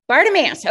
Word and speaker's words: Bartimaeus: How do you say his name? – Bartimaeus.